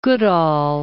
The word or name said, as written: Goodall